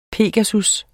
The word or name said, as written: pegasus